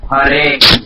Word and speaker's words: Hare: Hare.